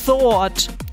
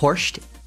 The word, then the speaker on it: Thoirt: Der Name wird T-H-O-I-R-T geschrieben. Also da denkt man ja irgendwie so, der heißt vielleicht Thort oder so. Gebt euch mal die Aussprache. – Das ist Horst.